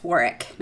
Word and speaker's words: Warwick: But, We don't insult Warwick Davis. – We definitely don't. It's pronounced Warwick, apparently. – Is it? – Yes, Warwick, not Warwick.